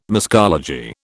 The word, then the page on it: muscology: British English